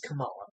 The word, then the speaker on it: Kamala: Yeah, no, this one's definitely going to cause a lot of confusion, but yes, her comics accurate pronunciation is Kamala.